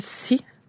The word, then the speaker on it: Assi: Puis Assi, c'est le mot, ça s'écrit A-S-S-I, c'est le mot pour terre dans la langue Innu-Imun. Mais chez moi, dans le dialecte, on va le prononcer Si. Fait que c'est vraiment comme, c'est comme le mot en anglais pour la mer,